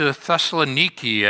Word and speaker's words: Thessaloniki: But then he comes to Thessaloniki, as the natives today pronounce it.